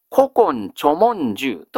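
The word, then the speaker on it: 古今著聞集: 古今と読みます。古今著文集と読みます。古今著文集、立花の成末というわけですね。で、この 3 つ、ぜひ繰り返してしっかり覚えてください。宇治周囲物語、実禁抄、古今著文集、これが鎌倉時代の世俗説話でございます。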